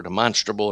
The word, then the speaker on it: demonstrable: Together, we are supposed to be God's temple, built according to his pattern, in overflowing with his glory in demonstrable ways, or demonstrable, it's sometimes pronounced.